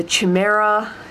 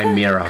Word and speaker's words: chimera: Chimera. – Chimera. Yeah, cool. You can tell I don't. – That's how it's normally pronounced in English. I don't know how it's pronounced in Greek.